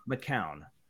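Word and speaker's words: McKeown: I think McKeon. It's pronounced McCown. I have heard go ahead. – I've heard McKeon, I've heard McCown. – I've heard McKeon, McEwen, and McCown. His hockey reference page says McCown.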